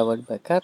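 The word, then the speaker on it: Houellebecq: O Acedii u Michela Welbecka, dobrze wymawiam? Tak, u Michela Welbecka, tak, Alterego, tak.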